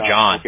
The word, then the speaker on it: John: No, but I go by John. – Okay. – Yeah, but I spell it the Bible, like J-O-H-N.